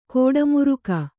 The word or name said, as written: ಗೌಡಮಾರ್ಗ